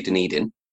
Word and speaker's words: Dunedin: I've already been told off for pronouncing this incorrectly, but I'm going to say Dunedin.